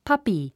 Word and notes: puppy: pʌ́pi; パピィ

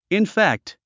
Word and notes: infect: infékt; インフェクト

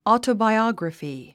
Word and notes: autobiography: ɔ̀ːtəbaiɑ́grəfi; オートバイオグラフィ

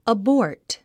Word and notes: abort: əbɔ́ː r t; アボート

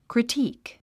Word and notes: critique: kritíːk; クリティーク

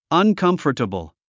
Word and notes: uncomfortable: ʌnkʌ́mftəbl; アンカンフタブル

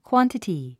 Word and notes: quantity: kwɑ’ntəti; クワンティティ

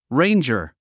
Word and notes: ranger: réindʒər; レェインジャァ

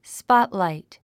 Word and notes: spotlight: spɑ́tlàit; スポットラァイト

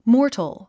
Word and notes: mortal: mɔ’ː r tl; モートル

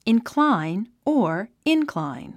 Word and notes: incline: inklǽin; インクラァイン

